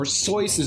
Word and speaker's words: Seuss: His mother is Henrietta Seuss, or Seuss is actually the correct way of pronouncing the name. Seuss is his mother's maiden name. That's where he gets the name Seuss from. Seuss always said that, you know, it rhymed with Mother Goose, or somebody pointed that out to him, so he was always okay with people pronouncing it Seuss.